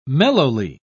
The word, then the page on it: mellowly: British English